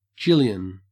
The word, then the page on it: jillion: Australian English